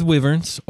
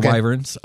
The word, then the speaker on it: wyverns: So let's start with wyverns or wyverns. It can be pronounced either way, and I kind of go back and forth. It just depends on how I feel.